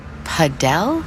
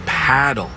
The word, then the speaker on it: Padel: Wait, is that Padel? – No, that's paddle. Rhymes with saddle.